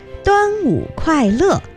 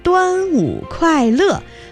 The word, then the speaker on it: 端午快乐: Quite right, except that your pronunciation needs to be improved. It should be 端午快乐, Happy Dragon Boat Festival, 端午快乐, or 端午安康, wishing you health during the 端午 Festival, 端午安康.